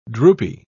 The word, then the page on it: droopy: British English